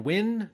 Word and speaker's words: Nguyen: And then the 1 in 50 cover is drawn by Dustin Nguyen. That's spelled N-G-U-Y-E-N,